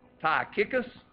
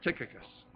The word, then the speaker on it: Tychicus: Because the hillbilly in me wants to say Tychicus. And that's not how you say it. It's Tychicus.